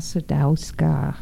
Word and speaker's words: Sadowska: This is Anna Sadowska, or Anya Sadowska, or Sadowska. – Okay. – Different ways of pronouncing my name, different sounds, different accents. Since this is an American radio, in English, I would say Anna Sadowska.